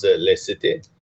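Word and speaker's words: laïcité: What they call is Les Cités, I believe is how it's pronounced. It's L-A-I-C-E-T-E.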